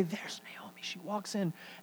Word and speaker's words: Naomi: And if you've ever read this book, and it's a book about women, you have a character named Naomi, properly pronounced Naomi.